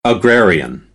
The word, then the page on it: agrarian: American English